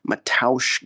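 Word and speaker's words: Mateusz: This time was headlined by ranked lightweight contenders Rafael Fiziev and Matoush Gamrot. That's how you say it, Matoush, in case you didn't know.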